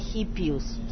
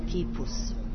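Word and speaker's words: Kipus (Гиппиус): Ona se ne zove Hippius, to je pogrešno prevedeno ali to se izgovara Kipus.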